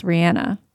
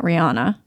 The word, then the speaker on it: Rihanna: Yeah, I found out that her name is actually pronounced Rihanna. Not Rihanna. We've been saying it wrong this whole time.